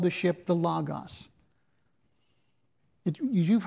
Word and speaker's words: logos: This is the Greek word logos. Some say logos, but the pronunciation would properly be logos.